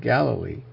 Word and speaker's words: Galilee: Of Galilee, I should say. The emphasis on the first syllable.